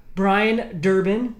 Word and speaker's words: Brian Durbin: But Brian Durbin is here.